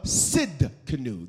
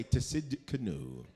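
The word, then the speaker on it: Tsidkenu: He's Jehovah Sid Canoe. The T is silent. Do not say to Sid Canoe.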